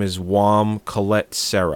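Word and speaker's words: Jaume: It's I don't even know how to pronounce his first name. It's J-A-U-M-E. – Jones. The same way you pronounce those horses in The Terrible Game. – Oh, okay. – There's a J next to a V. – His name is, I guess it's Wam. – Wam.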